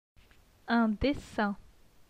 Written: Un dessin

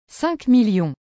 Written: 5000000

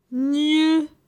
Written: Gne

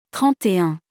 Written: 31

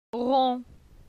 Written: Ron

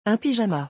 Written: Un pyjama

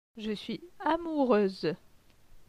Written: Je suis amoureuse

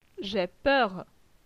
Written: J'ai peur

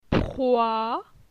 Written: Proi

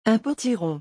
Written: un potiron

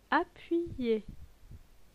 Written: Appuyer

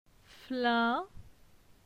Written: Flin

